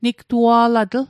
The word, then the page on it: nigtua'latl: Mi'gmaq